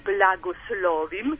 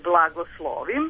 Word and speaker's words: blagoslovim: da ne izgovaraju tebe blagoslovim, nego tebe blagoslovim.